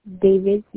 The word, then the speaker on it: Davis: Destiny Davis. – Can you spell that for me? – Destiny, D-E-S-T-I-N-Y, Davis, D-A-V-I-S.